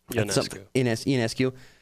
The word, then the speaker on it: Ionescu: Ionescu.